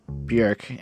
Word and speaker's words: Björk: just by the way, her name is pronounced more like Björk, and she rhymes with jerk.